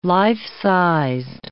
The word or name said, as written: life-sized